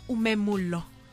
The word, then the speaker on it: umemulo: Now, speaking of your umemulo, am I saying it correctly? – Yes.